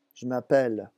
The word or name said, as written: je m'appelle